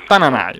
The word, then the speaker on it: Tananai: Eh, poi a 51, scendiamo eh, subito a 51, da 101 a 51, Tananai, giusto Tananai, come si, come si pronuncia? Ogni volta dice Tananai. Tananai, ok, Tananai a 51, Matteo Romano 51 e anche Dito nella piaga eh, e Donatella Rettore a, c- eh, a, 51, quota 51.